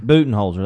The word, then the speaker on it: Budenholzer: I butchered that name. The coach for the Bucks. Do you not say the name? – I think you said it right, didn't you? – Budenholzer? – Budenholzer, that's it.